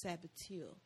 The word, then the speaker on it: saboteur: It means sabotage, but it's pronounced saboteur.